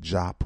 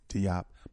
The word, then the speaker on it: Diop: Some people pronounce it Jop or Diop.